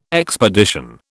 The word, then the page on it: expedition: British English